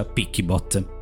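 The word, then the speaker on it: PiccyBot: L'app si chiama PeakyBot, scritto P di Palermo, I C C Y Bot, quindi PeakyBot, solo che si pronuncia PeakyBot.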